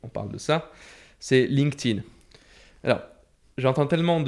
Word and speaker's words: LinkedIn: Parce que pourtant, c'est assez simple, hein, c'est LinkedIn.